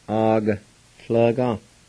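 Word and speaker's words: agatlvga: Second one, A-G-A. And that A will be silent right there with the G. T-L-V-G-A. It's A-ga. L-G-A. And the A with the G is silent, and it sounds like this. A-G-L-G-A. A-G-L-G-A. I'm thirsty. A-G-A-T-A-D-E-G-I. And the A with the G again is silent, so you just say it like this. A-G-T-A-D-E-G-I. A-G-T-A-D-E-G-I.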